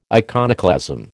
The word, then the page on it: iconoclasm: American English